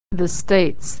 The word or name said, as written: the States